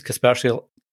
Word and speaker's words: Kaspersky: Kaspersky, yeah, you did.